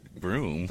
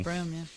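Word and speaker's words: Bruttenholm: I mean, who would think that Bruton-Holm is pronounced Broom? – Broom, yeah.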